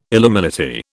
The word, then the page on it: illuminati: British English